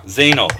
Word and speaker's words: Zeynel: His name is Ari Zeynel. Did I say that right? – Yes, Zeynel, yeah. – Zeynel.